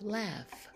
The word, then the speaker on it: laugh: Now, in these words, the H is pronounced like an F, and you probably have heard these words before. We have rough and we have laugh.